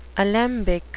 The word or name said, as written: alembic